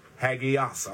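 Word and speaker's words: hagios (ἅγιος): The word holy here, the Greek word, is pronounced hagios. I'll spell that for you. Hagios. H-A-G-I-O-S.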